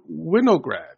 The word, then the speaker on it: Winograd: I hope I pronounced that right, Jacob. – Got it. – I've been told by Hody that it's sort of a running gag that people may mispronounce your surname. Is that true? – Yes. I've gotten many. I've gotten Winograds. I've gotten Wingard. Then, of course, there's people who mispronounce it on purpose in all sorts of insulting ways. But, no, kudos. You got it right.